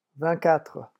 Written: vingt quatre

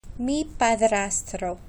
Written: mi padrastro

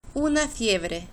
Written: una fiebre

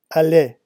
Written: allait

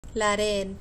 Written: la red

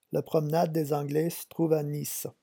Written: la promenade

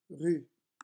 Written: rue

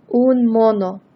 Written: un mono

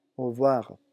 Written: au revoir

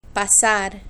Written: pasar

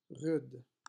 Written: rude

